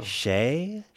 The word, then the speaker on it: Skeie: Shea. S-K-E-I-E.